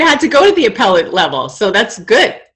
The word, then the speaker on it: appellate: Um, you know, even in the appellate level, I can't even believe I pronounced that wrong.